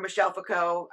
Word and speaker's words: Foucault: with like Michel Foucault and or Michel Foucault, I believe is how you properly say his name, and Derrida and whatnot.